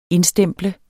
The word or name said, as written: indstemple